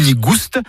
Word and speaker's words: gouste: Nigou ni gouste, avec prononciation du S originel que l'on retrouve dans le provençal goustus, goûteux, et qui a été remplacé depuis par l'accent circonflexe dans notre orthographe moderne.